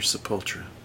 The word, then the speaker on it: Sepultura: Is it Sepultura or Sepultura? – Sepultura. – Okay. I've heard people pronounce it the other way. I've always thought it was Sepultura, too.